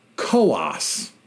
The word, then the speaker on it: Coos: First, Coos County, C-O-O-S, in New Hampshire is pronounced Coas, two syllable, rhymes with Haas.